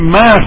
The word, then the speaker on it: مه: این مه خونده میشه، این هش تلفظ نمیشه و از نظر وزنی هم هیچ اشکالی نداره.